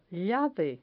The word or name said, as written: llave